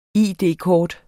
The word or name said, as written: id-kort